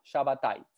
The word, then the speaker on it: Shabbatai: Por eso, eh, Tzvi, yo lo pronuncio Shabbatai. ¿Vos decís eh, ¿Cómo decimos? – Shabbatai, sí, qué sé yo.